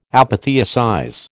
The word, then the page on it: apotheosize: American English